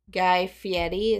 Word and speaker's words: Guy Fieri: And they had pictures of Guy Fieri. Is that how you say his name? – Yes.